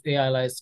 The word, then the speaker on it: AILYZE: I think it's AI-based analyze, right? So AILYZE.